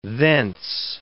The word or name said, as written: thence